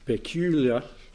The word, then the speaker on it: peculiar: A peculiar people. That's one word I've always had a struggle with. How do you say it? Peculiar. Thank you, that's about it. Liar at the end, but peck, liar.